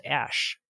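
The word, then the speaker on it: Æ: It's actually Nordic ligature, pronounced Ash.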